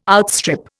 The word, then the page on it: outstrip: Indian